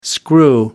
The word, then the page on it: screw: American English